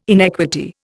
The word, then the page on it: inequity: Indian